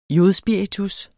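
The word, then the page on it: jodspiritus: Danish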